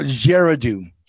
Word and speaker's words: Girardeau: Cape Girardeau? – No, Girardeau. – Girardeau. Okay.